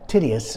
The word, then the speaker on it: Titius: Well, he left the synagogue, went next door to the house of Titius, is the way it's pronounced, Justus, a worshiper of God.